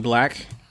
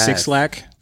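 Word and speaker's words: 6LACK: My jam of the week is Waves by Normani featuring Black. I pronounced it correctly this time, but it is Six Lakh.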